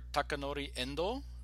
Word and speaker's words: Takanori Endo: All right, today I have with me here Dr. Takanori Endo, and I hope I'm saying your name right, Taka.